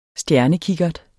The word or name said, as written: stjernekikkert